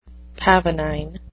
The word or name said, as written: pavonine